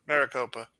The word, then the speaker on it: Maricopa: Kepioka County, Arizona. – Maricopa. – That one, too. – That's what... Pronounce it right, you idiot.